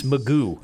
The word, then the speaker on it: McGough: Now, it's spelled differently. M-C-G-O-U-G-H. But as I was watching the Seahawks earlier in the preseason, I just kept hearing about Magoo.